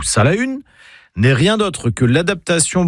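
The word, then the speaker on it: Salaün: Alors, déjà en breton, on prononce « Salahun ». Pas Salin, mais Salahun.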